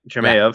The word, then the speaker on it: Chimaev: I mean, and I'm butchering his name. – Chimaev, right? Chimaev. Yeah.